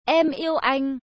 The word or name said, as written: em yeu anh